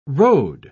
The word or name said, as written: rode